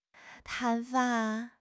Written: 砍伐

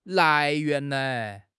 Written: 来源

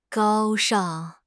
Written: 高尚

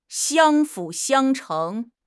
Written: 相辅相成

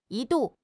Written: 一度

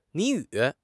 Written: 谜语

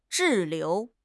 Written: 滞留

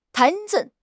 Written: Tai2

